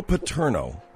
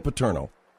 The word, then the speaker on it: Paterno: Pantino? – Joe Paterno. Joe Paterno. – I'm sorry. Joe Paterno, you know, that's what I'm saying.